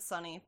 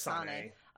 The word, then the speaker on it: Saneh: It's like S-A-N-E-H. – Yeah, I think Sané was the pronunciation. – Because it sounded like you said Sunny, but it's not Sunny. – Right, it's Sané.